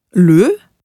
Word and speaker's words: le: Bei einsilbigen Wörtern auf E wie je, ich, le, der und so weiter, wird das E immer wie ein deutsches Ö gesprochen.